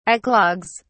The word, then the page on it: eclogues: British English